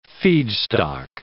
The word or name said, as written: feedstock